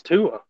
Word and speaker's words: Tua: Your t- Tua. it was. I think it's Tua.